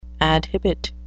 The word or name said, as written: adhibit